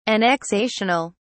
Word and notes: annexational: British English

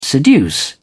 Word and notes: seduce: British English

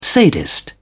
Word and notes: sadist: British English

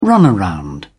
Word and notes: runaround: British English

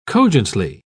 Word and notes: cogently: British English

